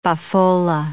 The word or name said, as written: boffola